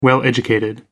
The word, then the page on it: well-educated: American English